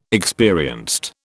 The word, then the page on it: experienced: British English